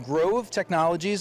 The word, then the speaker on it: Grov Technologies: So we're here with Brent Peterson of Grov Technologies.